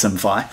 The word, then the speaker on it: Simfy: I see it's spelled SimFy. There was some discussion about whether it was SimFi or what it was, but I believe it's SimFi.